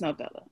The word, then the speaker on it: novella: Wait, am I saying that right? Is it novella? – Yeah. – It's novella.